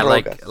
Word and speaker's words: drogas: Drogas.